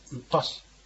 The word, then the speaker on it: upas: はい、ここで難しい発音はですね、このウッパシってありますね。これは雪なんです。ウッパシじゃなくてウッパシというふうに、子音なんですね。 S だけの発音です。あのローマ字で発音しますと UPAS ですから、ウパシというふうに発音していただけるとよろしいと思います。